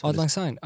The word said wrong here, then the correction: Auld Lang Syne: Auld Lang Syne. – Okay. Auld Lang Syne.